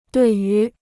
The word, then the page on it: 对于: duì yú